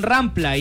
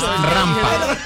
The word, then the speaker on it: rampa: No, es que sí, pero dijeron rampla y no se dice rampla. Rampla, y no rampa, se dijo rampla. Por eso. Y es rampa.